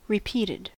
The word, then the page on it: repeated: American English